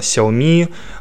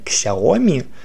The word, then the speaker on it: Xiaomi: Название Xiaomi произносится либо как Xiaomi, либо как Xiaomi. Ударение на последних слог.